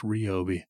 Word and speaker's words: Ryobi: Did you know Ryobi was Ryobi, as some people call it? – I call it Ryobi. – But apparently it's pronounced Ryobi.